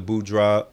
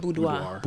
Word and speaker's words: boudoir: from the peer group that women have, and I've been seeing more of the boudoir, how do you say the word? – Boudoir.